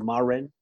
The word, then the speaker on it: Marin: It's pronounced Marin.